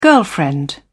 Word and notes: girlfriend: British English

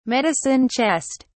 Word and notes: medicine chest: British English

